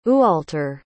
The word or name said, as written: ualtar